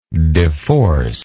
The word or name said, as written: deforcer